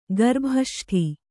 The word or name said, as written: ಗರ್ಭಷಷ್ಠಿ